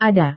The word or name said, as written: ada